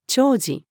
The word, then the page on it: 弔辞: female